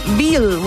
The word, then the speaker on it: Will: You think that veal would be like... – No, W-I-L-L. – Veal. – Veal. – W. Oh, yeah. Because in the German, they pronounce the W.